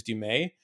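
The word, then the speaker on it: Du Mez: Dumais. It's spelled Dumez, M-E-Z is how at the end, but it's pronounced Dumais.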